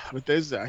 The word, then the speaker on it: Bethesda: No, like Bethesda.